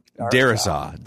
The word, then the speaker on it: Darrisaw: Darrisaw.